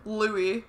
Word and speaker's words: Louis: Louis, first of all. – What the heck? There's an S at the end. – Yeah, but that's how you pronounce it.